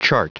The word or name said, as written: chart